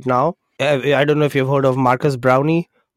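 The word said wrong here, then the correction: Marques Brownlee: I think I said his name right. Maybe I didn't. MKBHD. He's like the biggest YouTuber ever. So it's Marcus Brownlee. Brownlee.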